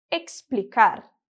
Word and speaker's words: explicar: Por exemplo, a palavra explicar, que significa explicar, vai ficar explicar. Mais uma vez, explicar.